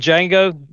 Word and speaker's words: Django: In Django, D is silent.